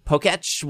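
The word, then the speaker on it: Pokétch: How do you say it? – Pokeech. – Pokeech. That's a Pokeech. – Like Etch a Sketch.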